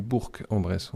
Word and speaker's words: Bourg-en-Bresse: Oui, parce que dans le hangar et en Bresse, on dit Bourg en Bresse. On prononce jusqu'au bout.